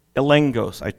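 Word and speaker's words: Elengos (ἔλεγχος): Different translations in this slot, you will see the Greek word englikos, translated evidence or conviction or certainty or being certain. Elengos. I mispronounced it a moment ago. Elengos. Elengos. In this sense, this is a legal term, and I like this again from the Greek language.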